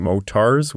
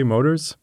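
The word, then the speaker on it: mortars: So I am going to stop saying motars with that strong T. I'm going to start saying it the way it's supposed to be, motors.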